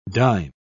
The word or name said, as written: dyne